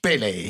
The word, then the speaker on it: Pelé: Maar we hebben het natuurlijk over Edson Arantes do Nascimento, beter bekend als Pelé. – Ja, dat spreek je gewoon helemaal goed uit. – Weet je, je kan over Pelé, je 28 podcasts houden, ben je nog niet uitgepraat.